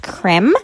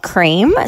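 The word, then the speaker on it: crème: Another product that I really want is the Ole Henriksen C-Rush Brightening Gel Cream. – Um, I'm editing this episode right now, and I actually think it's pronounced creme, not cream.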